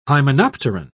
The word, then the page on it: hymenopteran: British English